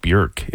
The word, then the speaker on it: Björk: Actually, her name is pronounced Björk if you're from Iceland.